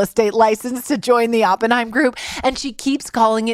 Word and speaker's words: Oppenheim: Like my 6-year-olds know Jason and Brett's last name is pronounced Oppenheim. So, yes, Janelle from Winnipeg.